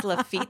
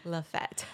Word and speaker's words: Lafitte: Even if he does correct me and he's like, no, it's actually supposed to be pronounced Lafitte the way you have it spelled, I will still say Lafette.